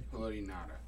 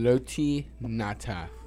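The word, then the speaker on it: Haloti Ngata: Though he won a Super Bowl, he also had Ray Lewis and Ed Reed and Haley Nagata in his prime. And then... – Haloti Nata. – I don't know. Thank you. I didn't know how to say it. Haloti Nata.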